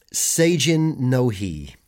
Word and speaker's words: Seijin no Hi: Even if just to witness something along the lines of, and I'm going to try and pronounce this, Seijin no Hi. – Oh, very good. That's exactly how it's pronounced.